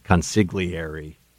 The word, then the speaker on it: consigliere: Oh, I know people pronounce it consigliere, but the G is silent, yo.